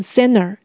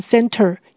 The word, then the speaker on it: center: 这个字美国人也常常会把它念成 center，也就是 t 没有念出来，因为嗯跟 t 的发音部位很接近，他们有时候就会把 t 给去掉，就会念成 center。当然，念 center 也是有的。